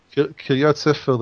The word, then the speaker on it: Kiryat Sefer: But basically, he said, whoever attacks, is it Kiryat Sefer? Is that how I pronounce it? – Kiryat Sefer, the town of the book.